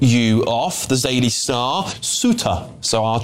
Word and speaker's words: Ceuta: Apparently that place is, James has said here, James H said it's pronounced Ceuta.